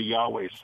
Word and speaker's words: Yahweh: So in order to hear it, the English language has to put the vowels in there to pronounce the Yahweh sound.